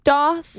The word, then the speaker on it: daith: Because like, it's spelled D-A-I-T-H. Yeah. Daith. But it's like, I think it's the root word is like a Hebrew word. Mm. So like technically it should be pronounced doth.